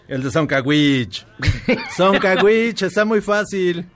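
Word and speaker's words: Dzoncauich: La D no suena, tendría que ser Soncaguich.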